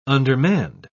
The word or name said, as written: undermanned